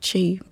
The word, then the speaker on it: Chee: I kept Chee. And I usually have to spell it, you know, C-H-E-E.